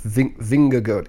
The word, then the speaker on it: Vingegaard: Vingegaard. It's a really tricky name, and it's spelled like Vingegaard, like you'd say that in English, but the Danish pronunciation is not that.